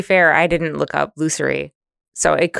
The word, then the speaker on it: lusory: Lusory attitude.